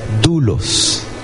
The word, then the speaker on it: doulos: La palabra griega es doulos. Se escribe D-O-U-L-O-S.